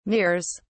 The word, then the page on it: mirrors: British English